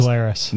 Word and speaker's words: New Glarus: brewed in Wisconsin, only in Wisconsin, employee-owned, new, how do you pronounce this? – New Glarus. – New Glarus Brewing Company Spotted Cow.